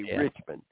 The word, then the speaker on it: Richmond: We don't pronounce the D, Richmond. We say Richmond. So when he says rich man, north of Richmond, there's no D in it, okay?